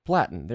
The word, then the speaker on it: Platon: How do you say his name? – I think it's Platon. – Platon. There you go.